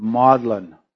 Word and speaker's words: Magdalen: It is spelled Magdalen, is the way we would say it, but the British pronounce it Magdalen.